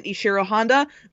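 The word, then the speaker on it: Ishirō Honda: I will say that he also apparently, and I will, congratulations to Legends for actually pronouncing it Ishiro Honda,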